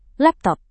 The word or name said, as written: laptop